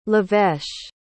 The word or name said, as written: leveche